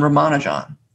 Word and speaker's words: Ramanujan: So some people say Ramanujan, that's the British pronunciation, but it's impossible for us to say it correctly because it's four unstressed syllables, the way that Tamil people say it. And I think it's just almost impossible for an English speaker to say four unstressed syllables in a row properly.